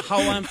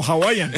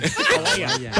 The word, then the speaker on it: Hawaiian: Hawaiian Pizza Day. – No, Hawaiian. Hawaiian. Al principio lo dijo bien.